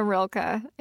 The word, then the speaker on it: Rilke: Rilke? Am I saying it right? You're the expert. – You have got Rainer Maria Rilke in Flora and Ulysses.